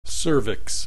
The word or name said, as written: cervix